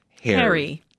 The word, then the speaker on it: HXRY: H-X-R-Y. – Remember that, H-X-R-Y. – Which is pronounced Harry.